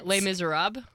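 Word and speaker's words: Les Misérables: Yeah, I've said it so wrong so many times that I like don't know how to say it anymore. Just like joking, I say it wrong. And so, say it again. – Les Miserables. – There we go.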